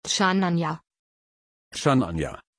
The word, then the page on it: chananja: German